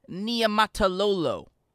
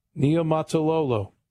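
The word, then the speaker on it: Niumatalolo: Ken Niematalolo. – Niematalolo.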